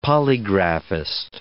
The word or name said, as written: polygraphist